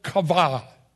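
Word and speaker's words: kavah (קוה): The Hebrew word is the Hebrew word kavah. Kavah. If you want to spell that, it would be Q-A-V-A-H.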